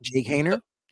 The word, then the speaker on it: Jake Haener: I like Jake Howard. I pronounced his name wrong. But Jake Haener? – Yeah, Haener, the Florida State, or Florida State, Fresno State.